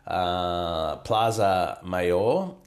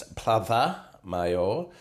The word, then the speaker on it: Plaza Mayor: I've been told that I'm not allowed to say Plaza Mayor. It's Plaza Mayor. – Plaza Mayor. See you in Spain. – Yeah. Because Z in Spain is TH, right. Pronounced TH.